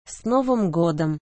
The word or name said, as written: s novym godom